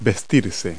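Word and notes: dress: verb

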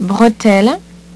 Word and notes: bretelle: French

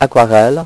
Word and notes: aquarelle: French

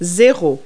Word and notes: zero: French

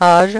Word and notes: age: French

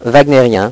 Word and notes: wagner: French

